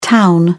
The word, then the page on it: town: British English